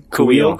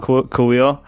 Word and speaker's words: Kuiil: Kuiil. – Kuiil.